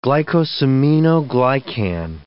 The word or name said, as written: glycosaminoglycan